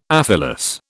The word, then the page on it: aphyllous: British English